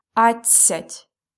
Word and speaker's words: адцять: We spell it A, D, C, Я, Т, and a soft sign. But we pronounce it really fast, that's why we don't hear D. It's адцять. Also, please be careful, because in these numbers, the stress always falls at адцять, одинадцять, дванадцять.